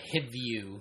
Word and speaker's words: Hivju: I think I said Hivju. Christopher Hivju.